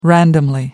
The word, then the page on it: randomly: American English